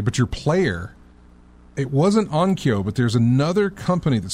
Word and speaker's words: Onkyo: I'm looking at a Denon and, oh, I'm going to mispronounce it, Onoko? – Onkyo.